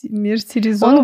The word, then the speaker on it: мерсеризованный: Я, во-первых, постоянно забываю, как правильно произносить. Мерсеризированный. – Нет, мерс... мерсеризованный.